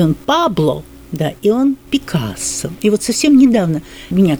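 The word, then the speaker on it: Picasso: Мы-то знали всегда Пикассо, потому что мы узнали из Франции. А во Франции кто бы ты ни был, у тебя будет ударение на последнем слоге.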